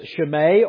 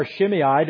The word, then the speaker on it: Shimei: His name is Shimei or Shimei, depending on the pronunciation that you prefer.